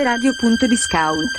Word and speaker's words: Radio Discount: Scrive radio.discount. Ma si pronuncia Radio Discount.